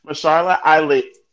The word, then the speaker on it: Mahershala: Well, Mahershala, I say his name wrong all the time. Mahershala Ali. To exactly what you're thinking about. Mahershala Ali. Yes, thank you for helping me correctly say his name.